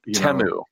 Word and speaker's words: Temu: And I'm trying to avoid, I don't like ads like those Temu ads where they, it's just a song about how great the brand is. – It's Temu.